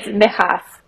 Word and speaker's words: Mychas: It's Mychas. It's how Mychas. – You say it. Yeah. – Mychas. Yeah. Like an I.